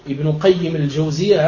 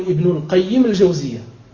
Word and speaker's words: ابن قيم الجوزية: إذا قلت ابن قيم الجوزية هكذا ينبغي أن تنطقه، لا تقول ابن القيم الجوزية، لا، ابن قيم الجوزية هذا مضاف مضاف إليه.